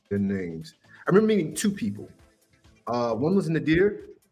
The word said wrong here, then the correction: Nader: I got the name right? – Yep. – And the other Nadir? – Was... – Oh, yeah. Nader, yeah. – Yeah, Nader. I pronounced the name wrong.